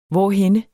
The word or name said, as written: hvorhenne